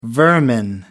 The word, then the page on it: vermin: American English